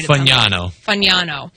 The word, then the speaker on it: Fagnano: It says Diego Pavia and Joe Fagano, the quarterback from UConn. I promise I'll get his name right. – Fagnano.